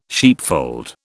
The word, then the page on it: sheepfold: British English